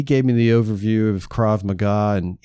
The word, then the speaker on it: Krav Maga: And he says, Well, it's pronounced Krav Maga.